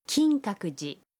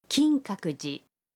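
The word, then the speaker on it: کینکاکوجی (金閣寺): کینکاکوجی. کینکاکوجی. – فرم ماس فعل رفتن میشه؟ – ایکیماس. ایکیماس. – و اگر محلی که میریم رو به اون اضافه کنیم و بگیم به کینکاکوجی میرم میشه؟ – کینکاکوجی نی ایکیماس.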